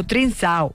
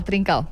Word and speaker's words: Trincão: Le pregunté a ChatGPT cómo se pronunciaba y dijo Trincão. – Trincao. Lo conocemos mejor como Trincao.